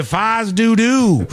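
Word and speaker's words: fais do-do: That's how they say it. The locals say Fize Dudu.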